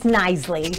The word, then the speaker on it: Knisley: Is it Kinsley? – It's Knisley. – Knisley. You know, the silent K just kind of throws you.